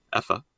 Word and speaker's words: Efa: It's Salva Rubio and illustrated by Effa, I think is how you say the name. EFA, Effa.